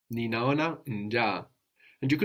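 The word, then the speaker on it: njaa: Because you pronounce both. – I pronounced both, which means the accent was on one of the A's, nja, rather than unja.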